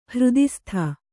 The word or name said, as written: ಹೃದಿಸ್ಥ